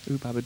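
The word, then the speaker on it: Oop-Pop-A-Da: Babs Gonzalez, Ubabada, is that how it's said, Paul? – Yeah, yeah. – Ubabada.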